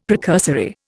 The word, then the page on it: precursory: Indian